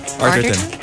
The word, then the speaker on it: Arterton: Arterton.